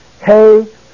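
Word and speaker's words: Hesaias (Ἠσαΐας): But notice the diéresis mark is over the iota. Therefore, it is splitting the alpha and the iota into separate pronunciations. So it is pronounced Hesaias.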